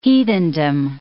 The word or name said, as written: heathendom